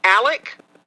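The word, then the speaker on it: Alex: It's supposed to be Alec in a formal setting. We have always pronounced it. So I would pronounce his name Alec Murdoch.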